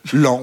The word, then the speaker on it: Laon: Et on prononce en général... – J'ai toujours prononcé... Laon. – Oui. Laon, oui, c'est Laon. Ça. On dit Laon, je crois, en général. Oui, Laon. Ça s'écrit L-A-O-N. Mais on dit bien Laon.